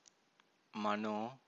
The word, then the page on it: mano: Pali